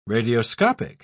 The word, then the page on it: radioscopic: British English